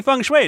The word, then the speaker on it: Feng Shui: Yeah, Boogie Woogie Feng Shui, it rhymes with flung clay.